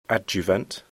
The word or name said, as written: adjuvant